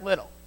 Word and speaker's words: Liddell: Chariots of Fire was a movie made about a runner named Eric Liddell. Or if you want to pronounce it Liddell, you can pronounce it Liddell, but we'll call him Eric.